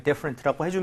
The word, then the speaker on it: different: D-I-F-F 그리고 E-R-E-N-T 정답입니다. 얘는 더를 쓰지 않고요. 그냥 different라고 해주면 되는데 예, 조금 추천드리면은 앞에 I의 강세를 많이 찍어서 T 사운드를 많이 없애버리세요.